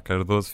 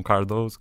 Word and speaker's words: Cardoso: pronunciar Cardoso, fica um bocado complicado. E eles diziam Cardoso, Cardoso.